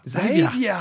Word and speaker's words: Xayvya: X-A-Y-V-Y-A. – Okay, so the X would be a Z sound. – Xavier. Holy shit. – Xavier. – Wow. – X-A-Y-V-Y-A.